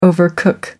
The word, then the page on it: overcook: American English